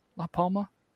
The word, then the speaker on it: La Palma: La Palma? – Yes.